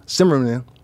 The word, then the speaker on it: Zimmerman: Trayvon Martin, who of course George Zimmerman, Zimmerman, that's the way you pronounce it, followed him mm-hmm.